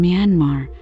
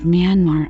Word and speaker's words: Myanmar: So the pronunciation of the last syllable of Myanmar as R of Burma, as by some speakers in the UK and most speakers in North America, is in fact a spelling pronunciation based on a misunderstanding of non-rhotic spelling conventions.